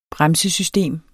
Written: bremsesystem

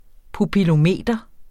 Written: pupillometer

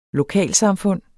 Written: LOKALSAMFUND

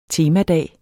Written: temadag

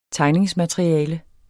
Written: tegningsmateriale

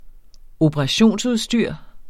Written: operationsudstyr

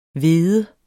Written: hvede

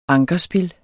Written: ankerspil

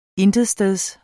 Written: intetsteds

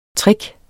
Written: trick

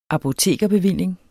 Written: apotekerbevilling

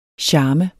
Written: charme

